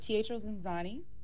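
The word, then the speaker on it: Teatro Zanzanú: you're in Chicago on a Teatro Ziazzini. Did I pronounce that right? – Close, but it's Teatro Zanzani, and it is like love, chaos, comedy, and dinner, and not just that.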